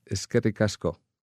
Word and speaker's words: Eskerrik asko: Eskerrik asko.